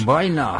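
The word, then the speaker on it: Wiene: El gabinete del doctor Caligari, estrenada un 26 de febrero de Ahí está el dato 1920, dirigida por el, eh, alemán Robert Weine, o se pronuncia de alguna manera. Baina. – Baina.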